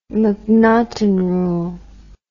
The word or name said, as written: mnaghten rule